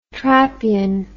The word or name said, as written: trappean